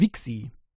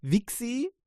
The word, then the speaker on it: Vixi: Also das, äh, das heißt Wixi. Äh, tut mir leid, ich kann auch, ich kann es auch das, das V äh, an, aus, äh, also das V als F aussprechen, so wie ich das bei Foxy gemacht habe, äh, weil Voxy wird es normalerweise, also in meinem Gedächtnis geschrieben, aber es ist Wixi ja, V-I-X-I, äh, Fixi wollte ich eigentlich nicht sagen, ja, V-I-X-I, also äh, Vixi, ähm, ja, Entschuldigung.